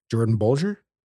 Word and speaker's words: Jordan Bolger: Jordan Bolger.